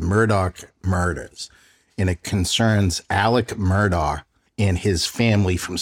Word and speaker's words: Alex: Murdoch is spelled M-U-R-D-A-U-G-H, but I think it's pronounced Murdoch. And Alex, A-L-E-X, is pronounced Alec.